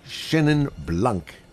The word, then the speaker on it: Chenin Blanc: It's the Ken Forrester Petit Chenin Blanc.